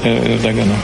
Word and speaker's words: Ердоган: Слово президенту Турецької республіки господину Таїпу Ерджагану. Реджепу Таїпу Ердогану.